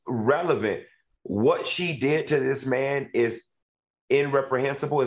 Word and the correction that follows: reprehensible: Is that the word? Reprehensible. Did I say it right?